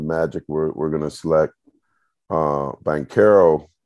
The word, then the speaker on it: Banchero: Banchero, I'm always pronouncing his last name wrong. – Banchero.